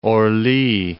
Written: Orly

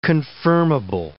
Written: confirmable

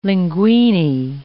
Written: linguini

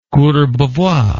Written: Courbevoie

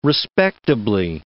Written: respectably

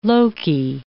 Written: Loki

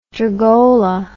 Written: drugola

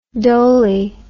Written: doley

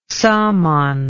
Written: saman

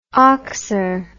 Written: oxer

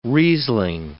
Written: Riesling

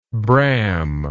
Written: bram

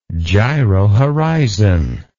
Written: gyrohorizon

